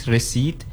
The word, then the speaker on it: receipt: D-E-T, debt. Mm-hmm. Same thing with this receipt. Mm-hmm. So yeah, I think it's the P and T is silent.